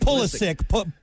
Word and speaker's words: Pulisic: Pulisic.